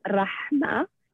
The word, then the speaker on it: Rahma: Okay, so my name means mercy in Arabic, and it's pronounced Rahma. And the H is just, you know, in the Arabic language, it's a much pronounced letter that is just not in the English language.